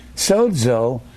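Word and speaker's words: Sozo (σώζω): This is a Z. You'll hear me pronounce it DZ, like in sozo. Sozo, you pronounce the Z DZ. Sozo.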